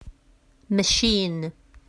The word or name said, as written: machine